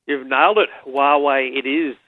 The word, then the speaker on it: Huawei: So Huawei it is, and it's a brand new P30 and P30 Pro.